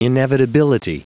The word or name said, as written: inevitability